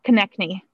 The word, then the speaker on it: Konecny: The only thing I can add to Flyers conversation is finding out how you say, is it Konecny? – Konecny. – Konecny. – Yes.